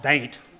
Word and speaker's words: Dante: And there were stories about there's a town down there spelled D-A-N-T-E, Dante pronounced Daint.